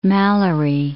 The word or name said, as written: Malory